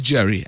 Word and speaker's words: Jerry: My name is Jerry, and you can follow me on all socials. It's Jerry Can Odu, J-E-R-R-Y-C-A-N, Jerry Can Odu is spelled O-R-D-U-H.